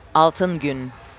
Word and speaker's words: Altın Gün: Altın Gün.